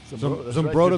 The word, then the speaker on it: Zumbrota: And, Gus, before we get to the Minnesota Vikings defense, you grew up in, I want to make sure I pronounce this right, is it Zumbrota? – Yeah, Zumbrota, right.